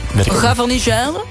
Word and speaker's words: Gavarnie-Gèdre: Gavarnie-Gèdre? – Ja, het is een Pau. – Pau. – Ja, en de andere... – Gavarnie-Gèdre.